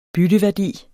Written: bytteværdi

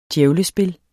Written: djævlespil

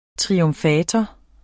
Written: triumfator

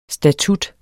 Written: STATUTTERNES

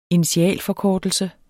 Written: initialforkortelse

